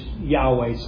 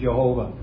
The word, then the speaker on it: Yahweh: which we pronounce as Yahweh, some pronounce it as Jehovah.